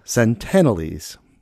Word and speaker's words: Sentinelese: And this tribe or band of people, I kept calling them the Sentinelese. I was missing a few letters in there. And it's actually the Sentinelese. So pronunciation, all that, corrected.